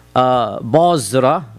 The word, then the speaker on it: Basra: Any reference of Basra, which is how it's pronounced, in the Sirah literature is to a small town south of Damascus.